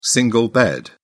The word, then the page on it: single bed: British English